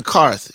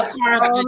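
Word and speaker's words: Qarth: And they're currently in Karth. Is that how you pronounce it? – Yes. – Mm-hmm. You can say Karth.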